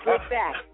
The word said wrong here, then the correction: Slick Back: Did I say that right? Flick back. – It's a pimp named Slick Back, just like a Tribe Called Quest, all in one.